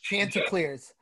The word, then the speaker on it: Chanticleers: And this is going to pique my attention to watch this game to see why Coastal Carolina, the Chandeliers, they're called. Or no, I'm probably pronouncing their name wrong. – Chanticleers. The only reason I know that, actually, really funny story.